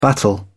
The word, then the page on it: battle: British English